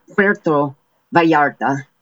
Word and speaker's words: Puerto Vallarta: Some of these include Acapulco, formerly Puerto Vallarta. Did I pronounce that right? I don't want to offend any of our Hispanic listeners. – Yes, you did. – I can't roll the R. Puerto Vallarta.